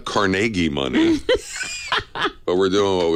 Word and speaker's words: Carnegie: Now you're saying it wrong. It's Carnegie. Carnegie Hall. Carnegie Hall. Come on.